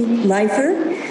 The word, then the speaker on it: Leifer: Chairman Carey, members of the committee, my name is Nancy Leeper. That's spelled N-A-N-C-Y. Last name is L-E-I-F-E-R.